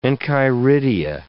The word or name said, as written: enchiridia